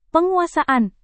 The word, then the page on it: penguasaan: Indonesian